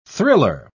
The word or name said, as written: thriller